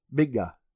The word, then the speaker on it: Mingua: Way, did I tell you that the pronunciation of that jerky was Minga? – Minga. – Yeah, there's a U in it, but they call it Minga.